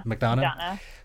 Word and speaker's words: McDonagh: McDonagh.